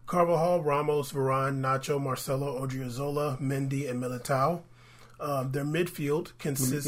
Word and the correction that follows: Militão: You gotta say it like the way you're supposed to. – Militão.